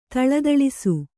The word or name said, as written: ಥಳಥಳಿಸು